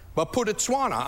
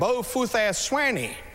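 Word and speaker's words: Bophuthatswana: Baputetswana. I mean, it's a long name, that big. And that's how you pronounce it. Well, the missions professor called it everything. Bofuthaswani.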